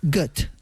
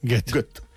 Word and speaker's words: Goethe: Y según he visto, se pronunciaría Goethe. – Goethe. – Mira, bueno, como Gotha.